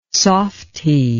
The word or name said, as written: softie